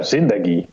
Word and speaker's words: Zindagi: Uh, Sindagi. Ja, Zindagi. Zindagi, oké. ik moet de klemtoon net iets, iets anders uh, zetten.